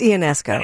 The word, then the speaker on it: Ionesco: Is it Ionesco or Ionesco? – It's Ionesco. – It's Ionesco. I've heard it pronounced the other way too, Ionesco. – Yeah. – But I always say Ionesco.